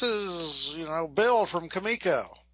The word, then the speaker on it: Comico: You idiots, whoever founded this company, the comic company, felt somehow the English pronunciation was Comico. So that is why, much as it still pains me to this day, in deference to this publisher, I will pronounce the name of the company in the ridiculous, delightfully ridiculous way it was presented, it turns out, as Shutter Comico.